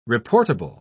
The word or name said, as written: reportable